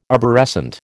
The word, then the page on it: arborescent: American English